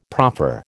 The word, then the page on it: proffer: American English